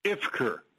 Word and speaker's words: Ivker: Ivker.